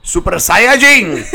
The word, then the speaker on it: Saiyajin: In Japan, I believe they pronounce it Super Saiyajin. – Yes, they do.